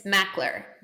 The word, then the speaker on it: Makler: It's Makler.